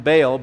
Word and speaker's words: Baal: The worship of Baal, we call him Baal, but really the proper pronunciation is Baal. It's just a little harder to say.